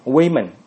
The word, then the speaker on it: women: 然后女人 woman， woman woman 变成 women 啊，读音变化了啊，读音读成 women。你看单数是 woman，复数是 women。